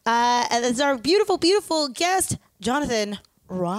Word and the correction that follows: Roy: It looks like Roy. – Yeah. – It's not. It's French, and French is a beautiful language.